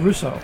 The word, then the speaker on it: Russo: Did I say your name correctly? – That's fine, Russo.